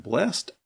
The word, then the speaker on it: blessed: If it's an adjective, it's pronounced blessed.